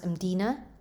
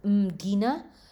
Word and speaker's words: Mdina: Also, I want to say that I went to this area here in Malta called, I believe it's pronounced Mdina. Mdina. It's spelled with M-D-I-N-A.